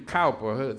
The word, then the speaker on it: Cowper: As the songwriter writes, William Cowper, spelled like Cooper,